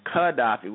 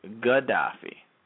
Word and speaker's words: Qaddafi: Now, they used to pronounce his name Qaddafi with a kuf in previous era, but right before they were about to murder him, they publicized his name in the media as Gaddafi, indicating that they would slice him.